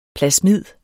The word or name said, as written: plasmid